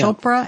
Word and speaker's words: Chopra: Rhymes with Oprah. – Mm-hmm, yeah. – Chopra and Oprah.